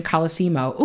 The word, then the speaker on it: Colosimo: In 1909, Torrio was invited to Chicago, Illinois to help Big Jim Colosimo. Ooh, I said it right the first time.